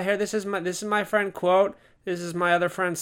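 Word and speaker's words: Kvothe: I don't know what the hell that's supposed to be, Of course, in the book, they explain it's pronounced quote, but I guess, just by looking at that, it's like, well, why don't you just name him quote?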